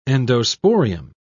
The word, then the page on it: endosporium: British English